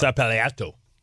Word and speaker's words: São Paulo: São Paulo.